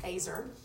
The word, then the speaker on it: ezer: Eve, if you remember, she was called a helper, Ezer. I've been saying it wrong. I always say Ezer, it's Azer.